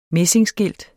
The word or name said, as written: messingskilt